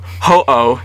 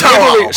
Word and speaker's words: Ho-Oh: So when describing certain works of art that is based off of English-speaking art historians often refer to them as ho-ho birds, a name derived from ho-oh, spelled H-O-O, which is the Japanese pronunciation of Fang Huang. So it's ho-oh. – So literally slap an H at the end of that.